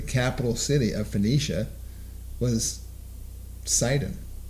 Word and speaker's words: Sidon: So it is a Phoenician word, Sidon. It's pronounced Said in their actual language. Said, Port Said, in their language, and it does mean fishing port, and in their language, and so it is interesting.